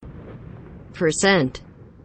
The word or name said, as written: per cent